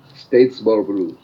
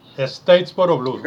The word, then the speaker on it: Statesboro Blues: ¿Statesboro Blues o Statesboro Blues? ¿Cuál es la pronunciación correcta? – Statesboro Blues. – Statesboro Blues, gracias.